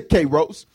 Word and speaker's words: kairos: Say kairos. Now I'm saying that with a little country vernacular. It's like kairos.